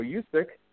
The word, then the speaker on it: Juszczyk: I always mispronounce his name. I believe it's Kyle Juszczyk.